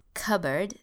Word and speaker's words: cupboard: Okay, notice the pronunciation of this word, cupboard. It's an interesting pronunciation.